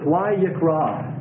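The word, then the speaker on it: Vayikra (ויקרא): The title of the book, the Hebrew title is Vayikra.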